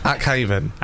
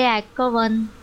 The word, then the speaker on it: Akhavan: And then her last name is A-K-H-A-V-A-N. So how would you... – Ackhaven. – Anyway, I YouTubed it. Because you can go on YouTube to find out how to pronounce words. And so here it is. Do you reckon this is right? – Desiree Ackhaven. – Oh, I like that.